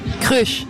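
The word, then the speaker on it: Kreusch: Ça se prononce comme ça s'écrit. Pour nous, en tout cas, c'est comme ça qu'on le dit. Ça peut être différent dans chaque pays, mais même en Belgique, ils ont tendance à compliquer les choses. Mais de nombreuses personnes prononcent Crush, et je préfère vraiment ça.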